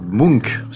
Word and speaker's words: Munch: Aujourd'hui, nous avons donc regardé une œuvre de 1899 intitulée La danse de la vie et réalisée par le peintre norvégien Edvard Munch. C'est comme ça qu'on prononce Angela, n'est-ce pas ? – C'est parfait.